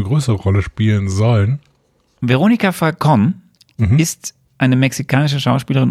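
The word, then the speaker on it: Falcón: Ich glaube, sie wird so ausgesprochen. – Falcon. Ja, stimmt, ist ja Mexiko.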